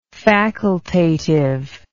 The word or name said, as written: facultatively